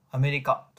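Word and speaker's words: 亜米利加: アメリカとなるわけですが、完全に読み方で選んでいることがわかります。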